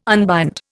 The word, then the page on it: unbind: Indian